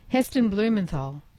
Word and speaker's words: Heston Blumenthal: Yes, Heston Bluthamol. Heston, yes, did I absolutely butchered that. – Heston Blumenthal. Yeah.